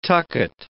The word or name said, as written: tucket